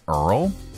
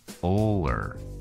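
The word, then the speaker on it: Ullr: How to pronounce, yeah, Oler. Okay, that's it.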